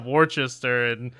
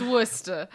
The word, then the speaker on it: Worcester: Worcester and all the other ones. – Oh, sweetie, it's Worcester. – I know it's Worcester. I knew that one. – You're pronouncing the R at the end.